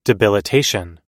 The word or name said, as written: debilitation